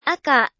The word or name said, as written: aka